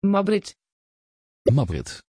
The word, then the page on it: Maybritt: Dutch